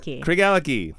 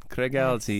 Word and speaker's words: Craigellachie: So, how do you say it? – Craigellachie. – Craig Allity, yes.